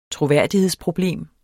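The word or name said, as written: troværdighedsproblem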